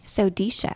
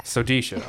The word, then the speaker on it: Sodecia: Um, how do you say it? – Sodecia. – Sodecia. All right.